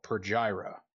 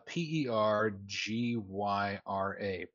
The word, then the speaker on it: Pergyra: became a city called Pergyra. Um, How do you spell that? Uh, P E R G Y R A, Pergyra.